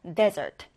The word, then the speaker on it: desert: 자, 일단 첫 음절에 강세를 두고요. desert.